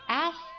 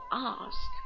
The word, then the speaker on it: ask: 然后往下看那个短语，动词短语叫 ask for， ask 英国人读作 ask，美国人读作 ask， ask for 表示请求、要求或者寻找的意思。